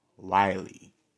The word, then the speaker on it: Wiley: Shout out to Marcellus Riley. Wiley. I want to say his name right, with a W. Marcellus Wiley.